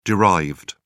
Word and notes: derived: British English

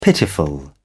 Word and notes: pitiful: British English

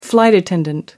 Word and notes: flight attendant: American English

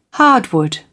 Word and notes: hardwood: British English

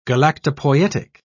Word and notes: galactopoietic: British English

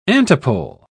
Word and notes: antipole: British English

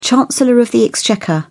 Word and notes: chancellor of the exchequer: British English

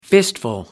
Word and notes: fistful: American English

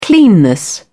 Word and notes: cleanness: British English

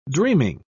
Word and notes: dreaming: British English

